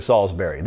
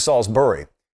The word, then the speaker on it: Salisbury: I think it was yesterday, and say, hey, I live in Salisbury, Maryland, but they don't live in Salisbury. That's the way I would pronounce it. The locals pronounce it Salisbury.